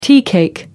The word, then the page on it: teacake: American English